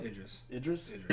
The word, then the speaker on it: Idris: Idris. – Icarus? – We're talking about race, say his name right.